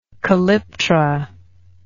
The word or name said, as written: calyptrate